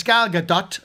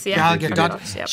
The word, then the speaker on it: Gal Gadot: It's Gal Gadot. I think I pronounced her name right. – You did. – Gal Gadot.